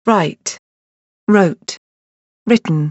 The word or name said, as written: write wrote written